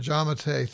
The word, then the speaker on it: Giammattei: Dr. Shawn Giammattei, thanks for being my guest today on Shrink Wrap Radio.